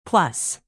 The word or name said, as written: plus